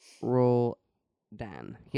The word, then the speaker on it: Roldan: R O L D A N. – Okay. I think. Roldan roll Dan. – Yes, correct.